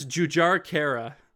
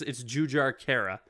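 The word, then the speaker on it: Jujhar Khaira: It's Jujar Kara. So in this one, you pronounce the J's. But yeah, it's Jujar Kara.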